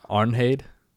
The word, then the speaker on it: Arnheid: So it's, I'll give you the spelling and I'll let you pronounce it. Me and you sometimes differ in our pronunciation. Arnheid. – Why did they do that? You said what? – Arnheid. Arnheid. – That's what you said the first time? – Arnheid. You could just say Arnheid, yeah.